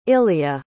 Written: illya

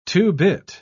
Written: two-bit

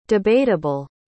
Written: debateable

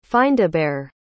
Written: findabhair

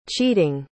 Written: cheating

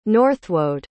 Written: Northwode

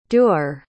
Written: duer

